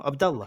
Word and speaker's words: Abdullah: No, no, Abdullah.